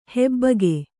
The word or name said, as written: ಹಬ್ಬುಗೆ